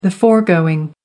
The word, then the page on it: foregoing: American English